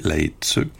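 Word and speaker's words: Lae Tseu: Peut-être plus, c'est la formation Laetseu. Donc, ça s'écrit L-A-E, espace T-S-E-U, Laetseu.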